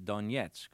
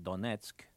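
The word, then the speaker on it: Donetsk (Донецк): Today we'll be hearing about our visit to the city of Donetsk. That's the Russian pronunciation. The Ukrainians there call it Donetsk.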